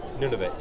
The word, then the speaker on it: Nunavut: You've actually sent me pictures from, how did you pronounce it, Nunavut? – Nunavut, yes.